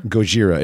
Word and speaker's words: Gojira: Gojira. It is pronounced the way the Japanese pronounce Godzilla. Gojira.